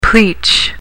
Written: pleach

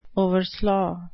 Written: overslaugh

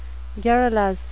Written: garrulous

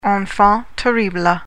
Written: enfant terrible

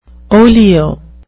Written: olio

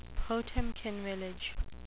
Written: Potemkin village

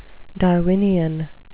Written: darwinian

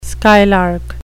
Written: skylark

